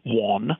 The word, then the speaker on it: Whann: My name is Sandy Whann.